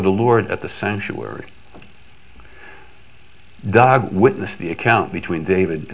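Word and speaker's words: Doeg: Now, some of you might be familiar with the name, and I'm going to pronounce it Dog.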